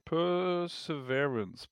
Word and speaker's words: Perseverance: Persever Perseverance? Persever Perseverance.